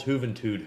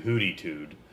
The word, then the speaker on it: Juventud: He calls Juventud Hootytood.